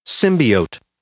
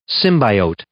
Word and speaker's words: symbiote: Symbiote. Symbiote. – So that both, you could say it both ways, I guess.